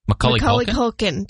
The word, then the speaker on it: Macaulay Culkin: With, how do you say his name? – Macaulay Culkin. – Yeah. There we go.